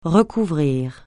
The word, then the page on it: recouvrir: francais